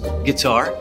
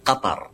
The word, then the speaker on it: Qatar: Americans like to pronounce it as sometimes guitar. – Excellent! – I've also heard gutter, but there's definitely no Qatar.